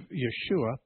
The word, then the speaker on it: Yeshua: Now, that's very close to Yeshua, the difference being that the Hebrew language has an SH sound. The Greek language does not have an SH sound in it. So there's no letters in Greek to pronounce to write the SH, so a simple S was used.